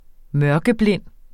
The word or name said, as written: mørkeblind